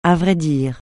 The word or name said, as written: à vrai dire